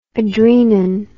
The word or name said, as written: adrenin